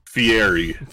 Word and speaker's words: Fieri: Is his last name Friari? – No. – Did I say it right the first time? – Furry? – Fieri.